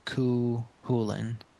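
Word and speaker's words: Cú Chulainn: Yeah, the second C is silent. It's the Cuchulainn.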